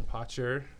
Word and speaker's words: Potcher: And with us also today is Jen Potcher.